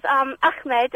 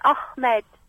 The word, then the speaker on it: Ahmed: And by the way you pronounce his Ahmed is wrong. You says Ahmed, and it's not, it's Ahmed.